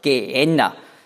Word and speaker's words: Gehenna: Das hat die Griechen haben das ausgesprochen als Gehenna. Die Griechen können nämlich das im Wortinneren kein H sprechen. Die können also nicht Gehenna sagen, so haben sie gesagt, Gehenna.